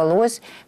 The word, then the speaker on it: лось: Сразу хочу сказать, что в русском языке при склонении существительного «лось» всегда ударение ставится на первый слог. Это на всякий случай, просто для наших слушателей, которые вдруг будут говорить, что на второй. Нет, только на первый.